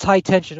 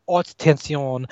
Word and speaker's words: Haute Tension: Yes, that's high tension, or if you want to go by the French pronunciation, haute tension.